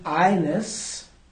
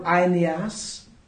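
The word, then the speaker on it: Aeneas: Sometimes there's an N at the end of his name, and I don't get it, but it is pronounced Ines, or Ineas, who had been bedridden 8 years, for he was paralyzed.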